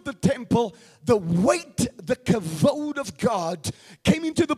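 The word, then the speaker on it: kavod (כבוד): Now we pronounce it as kabod, but it's really in the Hebrew. When you speak Hebrew, you say it this way, kavod. Somebody say kavod. So it's not the kabod of God like we pronounce it in the English language. The proper pronunciation is the kavod of God.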